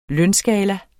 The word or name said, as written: lønskala